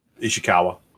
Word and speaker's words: Ishikawa: Excuse me if I mispronounce the name. Miki Ishikawa. – Ishikawa. – Yeah. Okay. – You did good.